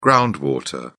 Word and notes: ground water: British English

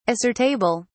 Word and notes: assertable: British English

